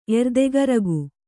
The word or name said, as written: ಎರ್ದೆಗರಗು